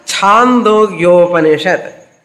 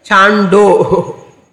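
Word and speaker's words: Chandogyopanishad (छान्दोग्योपनिषद्): In English, even spelling D-O you write, Chandogya, D-O you write, but you should not chant it as Chando. Chando. Chandogya Upanishad.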